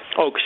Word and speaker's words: Ochs: And how do you say the last name? Oaks? – Oaks, yeah. O-C-H-S.